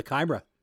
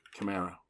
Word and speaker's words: Chimera: Oh, the Chimera. – Chimera. Yeah, they started off looking really good. Yeah, don't shake your head. You said you pronounced it wrong. I had to correct you.